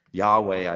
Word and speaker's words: Yahweh: Yahweh, I think, is the most common English pronunciation.